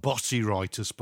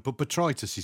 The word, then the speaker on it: botrytis: It's spelled botrytis, by the way. But botrytis is grey mould, and that will just spread through your plants like nobody's business.